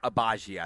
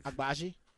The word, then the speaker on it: Agbaji: Is it Oshai Agbaji? – Agbaji, I believe. – Agbaji? Okay.